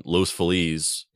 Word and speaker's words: Los Feliz: which is a restaurant in Los Feliz in Los Angeles. It's pronounced as Los Feliz. It should be pronounced as Los Feliz. That's how you say it in Spanish, but they call it Los Feliz.